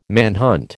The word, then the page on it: manhunt: American English